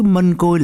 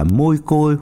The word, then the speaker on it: mân côi: Quý thính giả, chắc cũng biết chứ, mân côi là cách phát âm từ tiếng Hán Việt nghĩa là hoa hồng, rosa hoặc rosary. có nhiều nơi đọc là môi côi hoặc mai côi hoặc mai khôi.